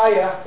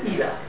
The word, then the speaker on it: Ida: It's not Ida, it's European, so Ian is spelled I-A-N, this is Ida, I-D-A.